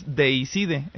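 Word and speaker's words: Deicide: Muchos, bueno, pues, correctamente le dicen 10 años porque es la pronunciación en inglés, sí. – Pero el nombre es latín y es Dei Cide.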